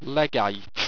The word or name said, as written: legite